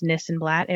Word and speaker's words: Nissenblatt: I am most active on Twitter at Ari this and that, as my last name is Nissenblatt. It rhymes.